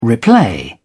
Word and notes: replay: British English

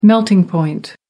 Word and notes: melting point: American English

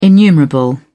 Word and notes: innumerable: British English

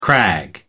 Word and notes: crag: American English

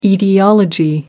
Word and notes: aetiology: American English

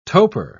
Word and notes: toper: British English

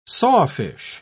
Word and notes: sawfish: British English

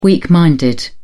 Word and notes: weak minded: British English

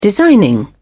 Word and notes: designing: British English

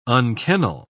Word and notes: unkennel: British English

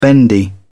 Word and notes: bendy: British English

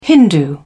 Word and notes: hindu: British English

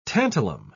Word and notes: tantalum: British English

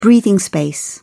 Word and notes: breathing space: British English

